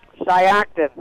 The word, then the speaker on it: Shiocton: Shiocton.